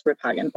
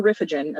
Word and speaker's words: Riphagen: I mean, if you say it, my maiden name is Riphagen, if I say it in Dutch, right? Riphagen or Riffagen, as people would say it. That was a mispronunciation of my name that happened a lot.